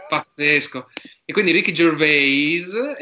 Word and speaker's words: Gervais: Ricky Gervais. Non, dirò mai, non, lo pronuncerò mai, mai più alla francese. Gervais.